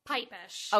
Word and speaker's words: Pipesh: Pipesh.